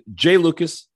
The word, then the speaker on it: Jai Lucas: Like we should know how to say his name. – Yes. So I apologize. It is Jai Lucas.